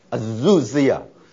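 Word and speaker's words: azuzia。 (ἐξουσία): Irene 要 exousia，哇这个字很重要，权柄或者放到 authority。约翰福音第一章十二节，神就赐给我们权柄，就是这个 exousia、啊、 authority 做神的儿女。那我们注意它的发音 ex，这个是双母音，对不对？本来是 wu 要加上这个 this 的那个子音，变成 azuzia。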